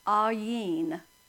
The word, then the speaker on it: Ayin: Or it may say A-Y-N. They're basically the same thing. It is the 16th letter of the Hebrew alphabet, pronounced Ayin, which means eye or to see.